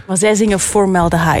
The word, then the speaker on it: Formaldehyde: Je maakt zo meteen kennis met hun gloednieuwe singer Formaldehyde? – Ja, dat is perfect uitgesproken eigenlijk.